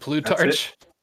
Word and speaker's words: Plutarch: It reminds me of some of the stuff that I've been reading from, we tried to pronounce this last time, Plutarch. Was it Plutarch? – Yeah. That's the proper pronunciation.